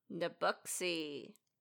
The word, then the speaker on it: Nebouxii: N-E-B-O-U-X-I-I. – Nabooxy.